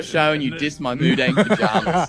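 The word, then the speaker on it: Moo Deng: Moo like a cow. D-E-N-G. Mudang.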